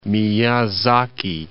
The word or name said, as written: Miyazaki